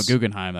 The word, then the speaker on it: Guggenheim: No, Guggenheim, that's right.